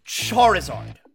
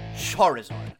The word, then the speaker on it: Charizard: Charizard. That's how you pronounce it. Charizard.